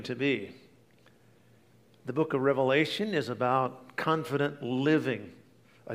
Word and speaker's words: Revelation: The last book of the New Testament is not entitled Revelations. A lot of times people pronounce it that way, but it's actually Revelation.